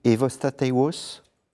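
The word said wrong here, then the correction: Ewostatewos: Tekla Aymanot et Eostateos.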